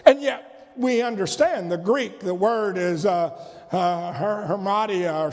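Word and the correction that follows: Hamartia (ἁμαρτία): or something like that. Hamartia, that's it.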